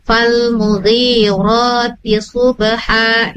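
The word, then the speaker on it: fal mugirotil subha (فَالْمُغِيرَاتِ صُبْحًا): Karena tadi di ayat ketiga nih, tadi adik Daniel bacanya fal mugirotil. Nah, sementara di situ ada tanda panjang ya sama fathah berdiri ya. Jadi fal mugirotil subha. Nah, ri-nya panjang dua harokat, ro-nya juga panjang dua harokat. Sama di pas ketika ayat keempat fa'atam nabihi naqa.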